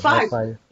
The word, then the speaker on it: Fiverr: Fiverr. – It's spelled F-I-V-E-R-R.